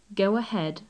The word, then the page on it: go ahead: English